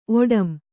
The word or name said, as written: ಒಡಂ